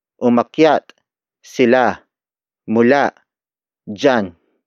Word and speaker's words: mula: Sila is spelled as S-I-L-A. It means they, the focus of this sentence. The next word is mula. Mula. Mula. Mula is spelled as M-U-L-A.